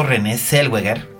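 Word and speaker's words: Renée Zellweger: Bueno, de hecho, René Zellweger. ¿Ves, Roberto Cavazos? Lo pronuncié bien.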